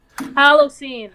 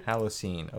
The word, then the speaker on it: Halocene: Can you pronounce that name for me? – Halocene. – Halocene. Okay, thank you.